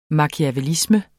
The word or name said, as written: machiavellisme